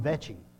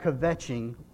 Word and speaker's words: kvetching: Do everything, okay, the word is spelled K-V-E-T-C-H-I-N-G. Kvetching is what I would say. The K could be silent. It might be vetching, okay?